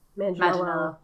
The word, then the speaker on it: Manganiello: Joe Manganiello. – Manganiello.